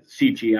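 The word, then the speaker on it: Sica Hollow: So we were in, I think we were out of high school, but we would go to Sheecha. I'm going to use that pronunciation because that's what we use. Sheecha Hollow.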